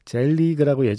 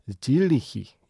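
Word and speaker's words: selig: 젤리그라고 예전에는 불렀는데 지금 열심히 발음을 찾아서 들어봤거든요. 그랬더니 질리히 예, 이게 좀 더 비슷하네요.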